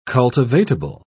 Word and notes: cultivatable: British English

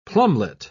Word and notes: plumelet: British English

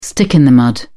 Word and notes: stick in the mud: British English

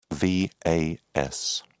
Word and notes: vas: British English

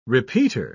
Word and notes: repeater: British English

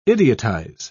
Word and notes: idiotize: British English

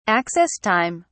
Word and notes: access time: British English

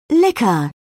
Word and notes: liquor: British English

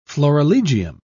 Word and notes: florilegium: British English

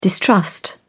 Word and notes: distrust: British English

